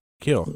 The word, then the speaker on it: Keel: Keel.